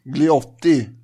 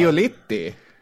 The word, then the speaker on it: Giolitti: Giolitti, det kommer vara så förbannat mycket italienska namn här. – Giolitti? – Giolitti menar jag.